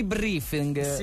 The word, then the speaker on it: briefing: Ah, abbiamo fatto un mini briefing. – Sì, sì. – Briefing è detto bene. – Sì, giusto? – Hai detto bene.